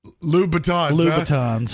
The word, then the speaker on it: Louboutins: Louboutins. That's what I can afford. The Louboutin. – That is how you pronounce it.